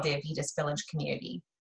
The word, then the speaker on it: Avitas: She is also the founder, we're going to have to cut because Avitas Village Community, is that how you pronounce it? – Yes. – Avitas. – Okay.